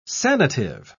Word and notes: sanative: British English

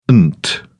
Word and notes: ent: British English

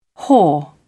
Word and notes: haw: British English

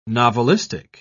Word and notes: novelistic: British English

